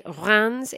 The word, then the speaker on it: Reims: And when I say Reims, it's spelled R E I M S.